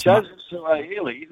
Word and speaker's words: Suaalii: Joseph Suaalii, he's the rugby league kid.